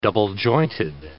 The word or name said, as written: double-jointed